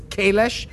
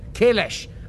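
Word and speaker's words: Kahless: She pronounces Kalesh correctly. It's actually correct. Kalesh.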